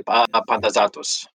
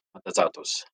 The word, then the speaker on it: Pantazatos: I would say Pantazatos. Pantazatos.